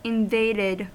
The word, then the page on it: invaded: American English